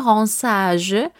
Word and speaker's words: rinçage: Ahora, la palabra ransage también quiere decir aclarado.